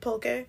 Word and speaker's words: pulque: I think that's how you say it right. Pulque or pulk? – I think you said it right. – Pulque.